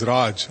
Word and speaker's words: Raj: My name is a bit difficult to pronounce, so for the sake of ease, you can call me or refer me to as Raj, R-A-J.